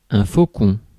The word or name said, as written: faucon